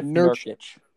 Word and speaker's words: Nurkic: Nurkic? – Yusuf Nurkic.